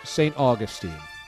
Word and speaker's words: St. Augustine: St. Augustine. Yeah, there that's up near Jacksonville, I believe, right? – Yeah, yes. I have relatives in St. Augustine.